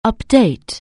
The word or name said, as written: update